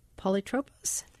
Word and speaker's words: polytropos: In a New York Times article about your work in the Odyssey, you talk about the first adjective to describe Odysseus, polytropos. Am I pronouncing that correctly? – That's fine. Yes.